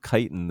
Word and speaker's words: chitin: How do you pronounce? It's chitin, right? – Chitin.